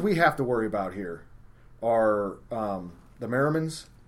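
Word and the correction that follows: Merriam's: and did I say that correctly? The Merrimans? – Merriams.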